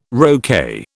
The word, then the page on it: roquet: British English